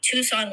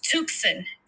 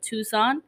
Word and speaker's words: Tucson: I used to think Tucson was pronounced Tucson for, like, years of my life.